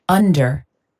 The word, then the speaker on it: under: Under. Under. – Ok. ¿Cómo sería view? And under. – Under. – Aunque la vemos U, pero suena el under.